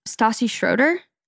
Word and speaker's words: Stassi Schroeder: Stassi Schroeder. Is that how you say her name? Mm-hmm.